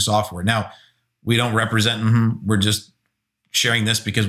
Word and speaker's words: Mm-hmm: And we discovered it recently this summer, and it's still out in beta, I believe, but it's called Mm-hmm. And yeah, I said that right. It's M-M-H-M-M, Mm-hmm, is the name of the app.